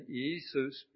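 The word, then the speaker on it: Iēsous: The Greek actually given in this passage is pronounced something like Eisus.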